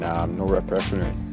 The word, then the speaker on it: norepinephrine: The main ones, main neurotransmitters in the brain are dopamine, serotonin, and norepinephrine.